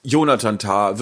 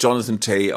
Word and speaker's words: Jonathan Tah: Ah, Jonathan Tah wird nicht Jonathan Tay ausgesprochen und so weiter.